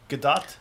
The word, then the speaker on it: Gadot: We just watched a Jimmy Kimmel with Gal Gadot. – Right, that's how we learned we've been pronouncing her Gadot.